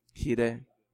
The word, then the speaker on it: Jireh: ¿Cómo se pronuncia eso? Jireh. Jehová Jireh.